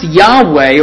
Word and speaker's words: Yahweh: It's pronounced Yahweh, or as in some very early translations, was even mistranslated into Jehovah.